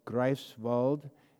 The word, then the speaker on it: Greifswald: Greifswald, Greifswald, in Germany. The you know, the E-I is pronounced I, and I-E is pronounced E.